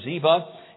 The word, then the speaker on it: Ziba: But David sought to show kindness to Saul and to Jonathan, and found out that there was a servant named Ziba, however you want to pronounce it. I pronounce it Ziba.